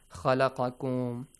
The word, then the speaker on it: خلقكم: أعبدوا ربكم الذي خلقكم. So the pronunciations of the letters ذال خا قاف. Okay, خلقكم خلقكم خلقكم. All right, make sure it's a heavy.